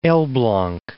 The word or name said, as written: Elblag